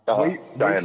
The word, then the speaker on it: Cheyenne: Now I have to ask, the show, like, Stargate Command was located in, I'm probably going to butcher the name, Cheyenne Mountain. – Cheyenne Mountain, yeah.